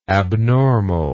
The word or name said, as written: abnormalness